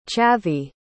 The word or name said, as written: chhavvi